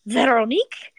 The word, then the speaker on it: Veronique: Like, I just always read it as, like, Veronique, or, like, Veronica, basically. – Yeah. – But yeah, the pronunciation was a very French Veronique.